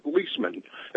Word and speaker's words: Liesman: He comes on and he explains Yeah, Steve Leisman, L-I-E-S-M-A-N, and he's pronounced as Leisman, and he explains to us what's going on financially and everything, you know.